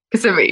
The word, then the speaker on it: Kissimmee: Kissimmee? – Kissimmee. – Kissimmee. – Close. – oh, how did you say it? – Kissimmee. Yep. – Kissimmee. Okay, it's not so much kiss.